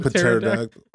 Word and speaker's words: pterodactyl: Think of those assholes that pronounce the P at the beginning of pterodactyl. A pterodactyl.